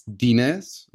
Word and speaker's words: Denez: It's Dinez, like D E N E Z.